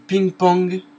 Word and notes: ping-pong: France; [piŋ pɔ̃ɡ]

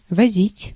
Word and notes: transporter: France; [tʁɑ̃s.pɔʁ.te]

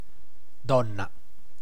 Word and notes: queen: American English; /kwiːn/

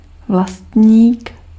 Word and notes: vlastník: /vlastɲiːk/

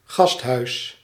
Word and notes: hôpital: France; /ɔ.pi.tal/

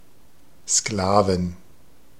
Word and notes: Sklaven: /ˈsklaːvn̩/

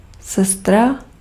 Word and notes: sestra: [ˈsɛs.tra]

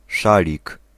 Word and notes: écharpe: [e.ʃaʁp]; France

